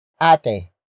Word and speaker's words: Ate: Ate. Ate. Ate. Ate is spelled as capital A, T, E. It means big sister, the beneficiary of the purchase and the focus of this sentence.